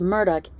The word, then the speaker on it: Murdaugh: I have heard their last name pronounced Murdaugh and Murdaugh. I heard Buster pronounce it as Murdaugh in court, so that's how I'm going to pronounce it.